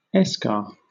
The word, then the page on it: eschar: British English